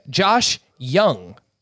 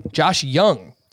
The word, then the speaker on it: Josh Jung: Josh Jung, not Jung. Josh Jung.